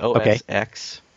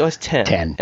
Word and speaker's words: OS X: I have said OS X before, which is wrong. It's actually OS... – No, they will... Yeah. – It's OS 10.